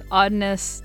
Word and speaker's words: Oddness: On her Instagram it says, Oddness that rhymes with hot mess, so...